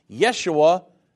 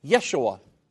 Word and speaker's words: Yeshua: And that's why Jesus is pronounced Yeshua in Hebrew, but it's the same word. So if you're Hebrew and wanting to speak in Hebrew, then you would say, when referring to Jesus, Yeshua.